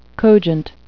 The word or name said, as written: cogent